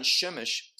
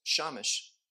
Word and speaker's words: Shemesh: Sometimes it's pronounced a little bit different, like the term son, Shemesh, sometimes pronounced Shamish.